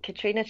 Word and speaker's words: Katriona: I'm Katriona Chapman. It's spelled K-A-T-R-I-O-N-A.